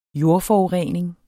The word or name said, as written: jordforurening